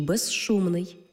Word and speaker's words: безшумний: У словах безшовний і безшумний, свистячий з плюс шиплячий ши всередині слова разом вимовляємо як жи-ши, безшовний, безшумний.